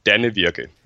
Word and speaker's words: Danevirke: Okay, so in Danish, it's called Danevirke.